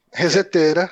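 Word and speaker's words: ResetEra: Reseteira. – Que, que reseteira. Esse, é, esse é o jeito certo de falar.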